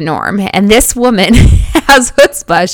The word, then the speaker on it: chutzpah: It is chutzpah. So even though it's a ch in the front of it, it's pronounced chutzpah.